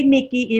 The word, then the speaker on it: Neiki: It's you can say Nikki. It's really another way that you can pronounce it, but we're going to go ahead and keep it as Nikki.